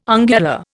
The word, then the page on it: ungula: Indian